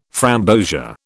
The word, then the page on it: framboesia: British English